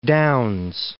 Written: Down's

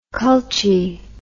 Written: culchie

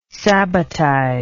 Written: sabbatize

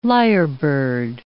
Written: lyrebird

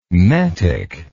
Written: metic

